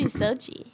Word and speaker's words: Socci: Because it should be Socci, phonetically. – Okay. Socci. Yeah. Or like, Socci, like bocce ball, because it's spelled like bocce, right? – Yep.